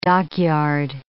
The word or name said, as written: dockyard